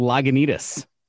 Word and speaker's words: Lagunitas: They're called Lagunitas. Did I say that right? – Yeah. I, I believe you pronounced it correctly.